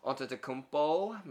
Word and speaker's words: Antetokounmpo: Uh, Giannis, Antetokounmpo, Milwaukee Bucks. I said that correctly.